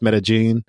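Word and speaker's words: Medellín: We also went to Medellin, even though it's spelled Medellin. So it's pronounced Medellin.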